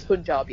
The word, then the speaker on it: Punjab: And I don't know if I'm pronouncing that word right. – It is Punjab.